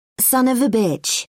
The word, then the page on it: son of a bitch: British English